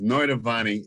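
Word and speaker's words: Noitavonni: It's Notavani. It's innovation spelled backwards.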